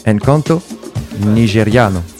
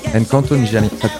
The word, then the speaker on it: Encanto nigeriano: Alors, petit point prononciation, on a fait le point en antenne. Encanto nigeriano. Encanto nigeriano. J'ai un peu pris un accent italien, je suis désolé, mais.